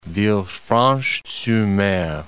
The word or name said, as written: Villefranche-sur-Mer